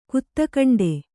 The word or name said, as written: ಕೊತ್ತಿಗೆಂಡೆ